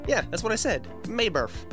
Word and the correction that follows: Naboth: I think you're saying it wrong. His name was Naboth.